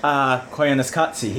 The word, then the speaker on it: Koyaanisqatsi: Is that how you pronounce it? – Koyaanisqatsi, yes.